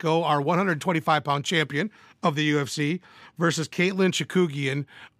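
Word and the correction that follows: Chookagian: or as Juice so abruptly and not very nicely sent me a text message saying, it's Chukagian. So sorry, buddy. I'll say it correctly. Caitlin Chukagian.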